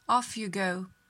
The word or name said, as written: off you go